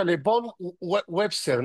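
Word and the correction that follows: Von Wobeser: Von Bobeser, Von Bobeser, Exacto.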